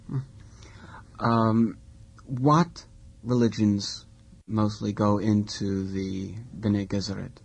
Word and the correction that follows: Bene Gesserit: Well, that I must have mispronounced. – I used Bene Gesserit because I was thinking of female Jesuits.